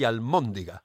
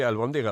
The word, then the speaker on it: albóndiga: es que aquí hay gente que la llama albóndiga y almóndiga. – Almóndiga, pénsate. Yo siempre le usé la B, de, Bolonia o de Barcelona. – Claro, claro. No, es que se dice así, albóndiga.